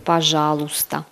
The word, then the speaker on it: пожалуйста: Пожалуйста. On le prononce пожалуйста.